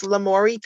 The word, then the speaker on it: Lamourie: Um, so that would be my email is lamourie pr at gmail. I'll spell that because it's a weird name, but it's basically Lamore, like French love with IE at the end.